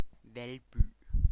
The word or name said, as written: Valby